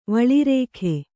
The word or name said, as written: ವಳಿರೇಖೆ